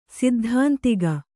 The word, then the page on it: ಸೈದ್ಧಾಂತಿಕ: Kannada